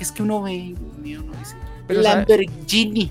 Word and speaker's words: Lamborghini: A mí personalmente me parece muy bonito, y no se dice Lamborghini. – Es Lamborghini. No, dice Lamborghini.